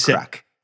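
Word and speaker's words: craic: And I believe it's spelled K-R-A-C-K. And that's humor. – Okay. – That's humor. – It's like crack.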